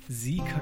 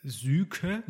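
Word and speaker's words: Syke: Wir gehen da heute in die Sieker Kreiszeitung. – Wichtiger Hinweis, alle Hörer des Deutschlandfunks wissen das, dass Süke, so könnte man es aussprechen, nicht Süke heißt, sondern Sieke.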